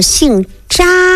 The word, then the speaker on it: 查: 还有呢姓扎，姓扎写的是哪个字，写的是查东西的查字啊，查阅的查。